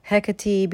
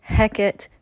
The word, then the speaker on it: Hecate: Now, I know there's more than one sort of canonized pronunciation, Hecate being one, Hecate being another, which is has the origin from Shakespeare's use of the name. Then there's also a more Greek-rooted version, Hecate.